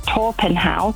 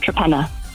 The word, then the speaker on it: Torpenhow: But I actually got married in a place that you would pronounce Torpenhow, because that's how it's spelt, but it's actually called Trepenna.